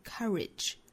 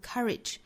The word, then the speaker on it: courage: 差别呢就在最后一个词上，就是 courage。那英音的话会读成 courage，美音这个词会读 courage，就是在 OU 字母组合这个地方啊，美音呢会倾向于发成一个 schwa sound、这样的音，所以有可能会读成 courage courage。